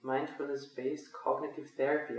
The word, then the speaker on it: Mindfulness-Based Cognitive Therapy: Und dann aus dem heraus, dass du deine Weiterbildung gemacht hast, bist du dann auch auf das MBCT, Mindfulness-Based Cognitive Therapy, habe ich es richtig gesagt? – Du hast es richtig gesagt, genau.